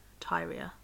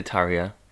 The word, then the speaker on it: Tyria: T-Y-R-I-A. Tyria Moore. We might just refer to her as Moore. – Moore. I think I'd say Tyria.